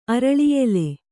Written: ಅರಳಿಯೆಲೆ

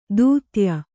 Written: ದೂತ್ಯ

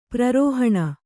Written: ಪ್ರರೋಹಣ

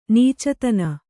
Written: ನೀಚತನ

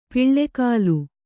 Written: ಪುಲ್ಗಲಿ

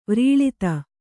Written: ವ್ರೀಳಿತ

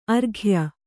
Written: ಆರೋಗ್ಯ

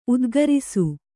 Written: ಉದ್ಗಾರಿಸು

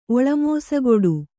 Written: ಒಳಮೋಸಗೊಡು

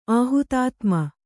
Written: ಆಹುತಾತ್ಮ

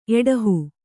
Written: ಎಡಹು

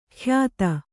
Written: ಖ್ಯಾತ

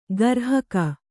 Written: ಗ್ರಾಹಕ

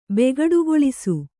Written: ಬೆಗಡುಗೊಳಿಸು

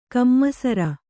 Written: ಕಮ್ಮಸರ

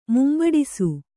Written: ಮುಂಬಡಿಸು

